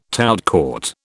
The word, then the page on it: tout court: British English